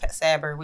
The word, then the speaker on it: Sabir: Again, I've been thinking about my brother a lot, and his name was Sabir, or in Arabic, Sabir, pronounced Sabir, which means patience.